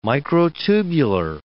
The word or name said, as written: microtubular